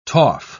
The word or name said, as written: toff